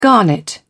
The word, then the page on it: garnet: British English